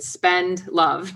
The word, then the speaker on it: Spendlove: My last name is exactly like it sounds, but people think it's spelled more complicated. Spend love. So instead of spend money, spend love.